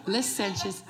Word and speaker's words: licentious: The word she said it, although it's over with, but she said licentious. It's pronounced, if I'm not mistaken, licentious.